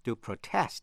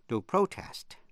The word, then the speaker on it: protest: Động từ protest có hai cách đọc, hai cách phát âm. To protest, nhấn mạnh vần nhì, hay là to protest, nhấn mạnh vần nhất. Protester, P-R-O-T-E-S-T-E-R, người phản đối.